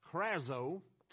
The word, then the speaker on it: krazo: It's called krazo, however you want to pronounce it. K-R-A-Z-O, excuse me.